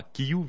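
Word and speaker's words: kiu: Par exemple, si on rencontre quelqu'un et si on demande qui es-tu, ce sera quiu viestas, qui es-tu. Quiu, c'est U comme individu. Kiyu, le U se prononce toujours U en espéranto.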